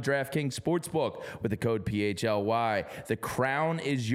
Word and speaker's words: PHLY: I've seen some people discussing how we pronounce it and all that. It's PHLY.